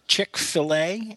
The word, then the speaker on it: Chick-fil-A: Now, is this pronounced Chick-fil-A? Is that how it's pronounced? – Yes.